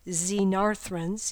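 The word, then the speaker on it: Xenarthrans: You'll see the phonetic pronunciation right next to it, Xenarthrins, even though it starts with an X.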